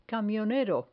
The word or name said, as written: camionero